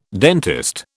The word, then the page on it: dentist: British English